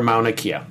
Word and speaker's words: Mauna Kea: We are Mauna Kea.